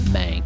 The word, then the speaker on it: Mank: So, you know what rhymes with dank? Shank? Crank? Flank? Mank.